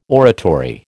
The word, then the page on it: oratory: American English